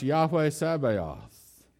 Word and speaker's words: Yahweh Sabaoth: And in Hebrew, it's pronounced Yahweh Sabaoth.